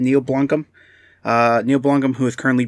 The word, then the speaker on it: Blomkamp: I think it's Blomkamp.